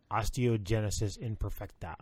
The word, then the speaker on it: osteogenesis imperfecta: Osteogenesis imperfecta.